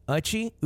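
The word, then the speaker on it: Uche: Uchi. Uchi. You shy? – It's you? I thought it was O. – No, you.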